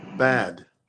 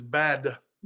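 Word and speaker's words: bad (בד): The cool part of that is that word alone in Hebrew is B-A-D, bad. – Really? That's the word for alone. Okay. – That's even how you pronounce it, is bad. Wow. With an extra emphasis on the D, bad.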